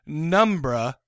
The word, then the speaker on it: Pneumbra: There's one called Cold Sweat that I've never seen, but there's also one called Penumbra or Numbra. If the P is silent, it's spelled P-N-E-U-M-B-R-A, which is on Netflix.